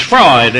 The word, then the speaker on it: Freud: It's spelled differently, isn't it? It's Freud, it's not Freud.